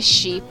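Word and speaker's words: Shi (四): Perché nel Sol Levante questo numero si legge Shi, la cui pronuncia in giapponese risulta identica a quella della parola morte, ed è per questo che si tende a preferire l'utilizzo della parola Yo, che significa sempre 4.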